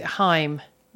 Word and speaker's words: Haim: And then I've had a little Google and you actually pronounced it Haim, rhyming with time.